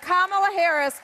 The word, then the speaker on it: Kamala Harris: Joe Biden and Kamala Harris are not the legitimate president and vice president of the United States. – Hey, mad respect. She actually pronounced Kamala Harris's name correctly.